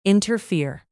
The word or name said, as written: interfere